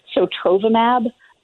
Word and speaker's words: Sotrovimab: The Regeneron drug is called Regen-Cov, and the other one, Sotrovimab, that anything that ends in the letters MAB, that's for monoclonal antibody.